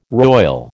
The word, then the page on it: roil: American English